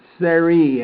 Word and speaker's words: Sarai: Um, and Sarah, the wife, Sari actually is the way you pronounce it because that uh, the way it looks here is, Sarah, Sarah, Sarah. There's no H on it.